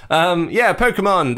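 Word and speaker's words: Pokémon: Moving away from collecting racing trophies to collecting the Pokemon, which is how it's pronounced, by the way. That's what the little umlaut is at the top there. That's what that means. Pokemon.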